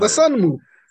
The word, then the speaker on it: Dosunmu: And you know, you see that Primo picked up Ayo Dosunmu. I'm butchering his name. Shame on me. I'm a Bulls fan. I just call him Dosunmu. You had it right.